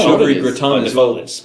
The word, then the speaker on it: au gratin: Au gratin. – Au gratin. – Oh, yeah. Well, if you... I mean, you say au gratin is the correct French pronunciation, but in English you would say...